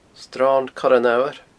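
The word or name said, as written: Sron Coire an Fhamhair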